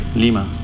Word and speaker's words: Lima: Yeah, it's the same. A little longer on the I. Lima.